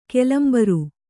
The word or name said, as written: ಕೆಲಂಬರು